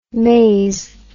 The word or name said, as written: mase